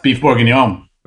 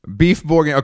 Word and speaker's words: bourguignon: Beef bourguignon. – Beef bourguignon.